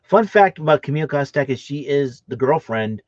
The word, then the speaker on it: Kostek: Kostek. – Okay, thank you. Camille Kostek.